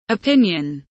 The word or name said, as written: opinion